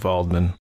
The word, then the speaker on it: Waldman: And it's with a W, but that's how they say it, Waldman.